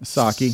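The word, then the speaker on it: Psaki: Pasaki. Like a pair of socks. – I call her Pasaki. – If you look at the spelling of it, Rick, it scares you. Just say Saki.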